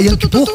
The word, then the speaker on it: Yom Kippur: Como é que fala Yom Kippur?